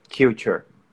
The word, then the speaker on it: culture: Esse aí nós vamos mudar ele, a pronúncia, porque a forma correta que a gente pronuncia é culture. – Culture. – não culture. Isso é bem comum de acontecer, porque a gente acaba sendo influenciado pela pronúncia brasileira da palavra, né? Mas nesse caso aí, culture, tá? – Culture.